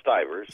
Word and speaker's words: Stivers: I guess he's, I guess that's how you say his name, Stivers, right? – Stivers. – Stivers, all right.